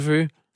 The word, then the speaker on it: Vøge: Der, det er simpelthen, det udtales som et F. – Nå, okay. Ikke Lasse Føge.